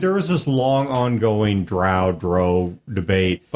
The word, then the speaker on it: drow: The online pronunciation guide from Wizards of the Coast says it is drow, not drow. It is not drow.